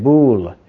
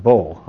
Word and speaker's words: Bul: Here's how you say it, Bul. It's not Bull, okay? It's Bul.